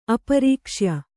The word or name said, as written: ಅಪರೀಕ್ಷ್ಯ